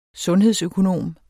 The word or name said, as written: sundhedsøkonom